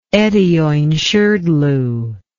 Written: etaoinshrdlu

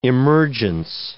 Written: emergence

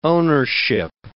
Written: ownership

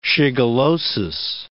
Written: shigellosis

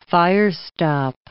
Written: fire-stop

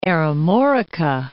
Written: Aremorica

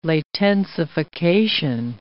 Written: latensification

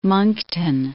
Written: Moncton

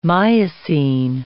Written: Miocene

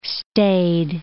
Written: staid

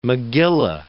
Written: megillah

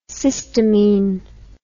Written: cystamine

